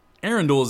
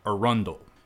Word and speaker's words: Arundel: Arundel is a family name Marylanders will become quite familiar with, though you might pronounce it something more like Arundel.